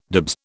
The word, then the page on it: dibs: British English